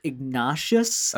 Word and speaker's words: Ignatius: Excuse me, Greg, but I think it's pronounced Ignatius.